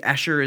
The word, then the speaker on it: Escher: Or MC, I think Escher is how you say his name.